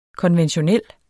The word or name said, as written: konventionel